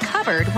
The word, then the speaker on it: cupboard: When we come back, why it's pronounced covered when it's spelled cupboard?